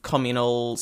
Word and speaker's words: communal: And actually respect and enjoy, like, I think there's something very nice about the communal singing and communal services and that kind of thing. – Mm-hmm. – Communal is the British pronunciation.